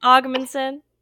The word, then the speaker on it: Ogmundson: No, just like Ogmundson.